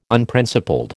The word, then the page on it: Unprincipled: American English